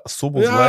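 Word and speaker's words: Szoboszlai: Ich glaube, Sobo, Soboslai. – Soboslai.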